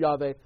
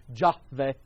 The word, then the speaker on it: Yahweh: because sometimes it's Yahweh and sometimes it's Jahweh. But remember that there is no such term, there is no such sound as J in the Hebrew language. It's the Hebrew letter Yod, pronounced like a Y.